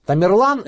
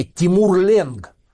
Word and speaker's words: Тамерлан: Тамерлан – это такое европеизированное произношение словосочетания «тимур-ленг». Железный хромец, он действительно был хром на одну ногу.